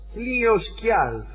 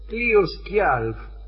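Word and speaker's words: Hliðskjálf: Como reina de los dioses, sólo ella tenía el privilegio de sentarse sobre el trono Hlioskjalf, o yo lo pronunciaría Hlioskjalf, porque la J por ahí va como oye. Hlioskjalf, cuyo dueño era Odín.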